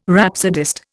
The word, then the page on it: rhapsodist: Indian